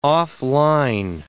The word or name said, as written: off-line